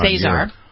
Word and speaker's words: Azar: And yesterday you had Alex, is it Azar? How do you pronounce that? – Alex Azar.